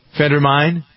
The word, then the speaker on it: Phentermine: He was trying to lose weight, and he was using Phentermine. Is that how you pronounce it? P-A-G-N? – Uh-huh. – Phentermine?